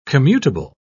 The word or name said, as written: commutable